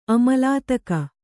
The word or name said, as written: ಅಮಲಾತಕ